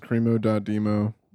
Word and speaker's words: cremo.demo: My name is cremo.demo. – You want to spell that? – C-R-E-M-O-D-E-M-O. People are like, that's cremo. No, not for me, dude. Cremo, dude. Boom. – D-E-M-O?